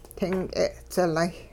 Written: ting k'e'dzilay

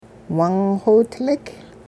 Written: wung-xiwidlik

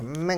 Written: ming

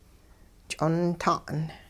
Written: ch'onta'n

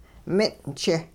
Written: min'ch

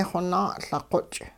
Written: xonaa-Liqoch'

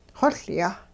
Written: xoLyah

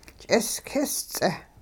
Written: ch'isk'is-ts'eh

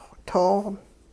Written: too-

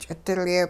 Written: ch'idilye

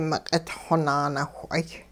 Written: miq'itxwo naanawh'ay

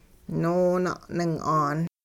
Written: noona'ning'aan

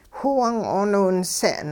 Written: whiwung 'ooniwinse'n

